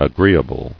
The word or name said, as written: Agreeably